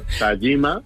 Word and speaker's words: Tajima: O sea, es Kazuhiro Tajima. – Tajima. – Tajima. – La J en Japón se pronuncia como una Y.